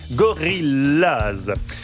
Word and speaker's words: Gorillaz: Se prononce comme en anglais Gorillaz.